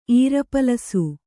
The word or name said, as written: ಈರಪಲಸು